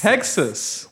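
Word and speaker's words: Hexxus: Hexus. – Hexus.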